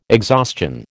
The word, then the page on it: exhaustion: American English